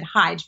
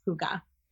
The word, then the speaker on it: hygge: but before that I looked up how to pronounce it hyge, but it is hygge.